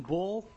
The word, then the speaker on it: Bull: My name is William Bull. Last name is spelled B-U-L-L.